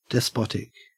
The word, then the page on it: despotic: Australian English